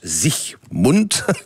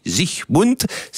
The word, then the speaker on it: Siegmund: Alors, l'homme fort qui a permis cette victoire, c'est Ulrich Siegmund, en allemand ça se prononce Siegmund, pas d'alga, je n'ai pas dit Sieg Heil, j'ai dit Siegmund.